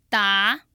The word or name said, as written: da2